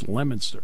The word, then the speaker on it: Leominster: H-I-L-L. It's Haverhill. They have a town up there, L-E-O-M-I-N-S-T-E-R. It looks like Leominster. It's Lemonster. Welcome to New England.